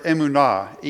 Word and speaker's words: emunah: And I talked to you about the word emunah, E-M-U-N-A-H, if you're going to spell it, in the Hebrew.